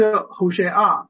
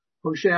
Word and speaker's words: Hosea: 那么这个何西阿书呢他的这位先知啊，他的名字这个 Hosea 这个他是 Hosea 啊 Hosea 其实是 Hosea，他真的犹太人以色列人来读的啊，就是读成这个 Hosea 啊。